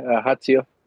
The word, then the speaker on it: Ajaccio: So Friday the 5th, Lyon versus Ajaccio. Is that how you say it? – Yeah, Ajaccio.